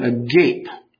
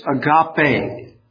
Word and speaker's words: agape: Now, if you put that word into English letters, it looks like the word agape. But it's actually three syllables in Greek. It has nothing to do with what we mean by he's all agape, you know, something like that. But it's agape.